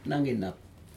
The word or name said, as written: naginap